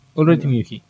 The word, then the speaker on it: Ulrich Mühe: Ulrich Mühe, ¿cómo lo pronunciaría? – Ulrich Mühe. – Ulrich Mühe, muy bien.